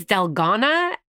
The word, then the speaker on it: Dalgona: Is that how you say it? – Oh, my God. Wait, you're just making me realize something. I think it's Dalgona. – Dalgona. – It's South Korean, yes.